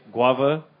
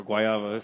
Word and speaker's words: guava: This is a cask of Epic Brewing Hopulent with, and I always, there's two ways to pronounce it, guava or guayava if you're Latino.